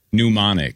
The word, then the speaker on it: pneumonic: Pneumonic. – Pneumonic. – As in pneumonia. – Yeah. Okay. All right. – That's just okay, silent P.